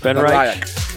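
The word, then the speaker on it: BenRiach: R-I-A-C-H. Ben Riach.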